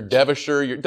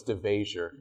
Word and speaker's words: DeVasher: I told them how to pronounce my name. I don't want this whole Mr. DeVasher. No. It's DeVasher, and your table is ready.